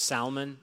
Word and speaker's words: salmon: He is a pronounce the L in salmon person. It's salmon,